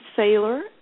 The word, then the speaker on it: Sayler: And for your listeners, I also have put together, if they go to Sharon Saylor,